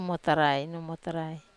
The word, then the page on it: muotarai: wamesa